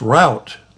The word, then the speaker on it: Route: "It's Route, like rhymes with snout, Route Hog."